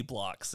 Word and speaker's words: Bloc: I mean, it is spelled B-L-O-C, but every character is just a little wooden cube.